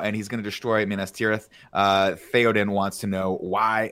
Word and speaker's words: Minas Tirith: And he goes, Minas Tirith. Did I say it right, Andy? – Minas Tirith. – Minas Tirith. – Yeah.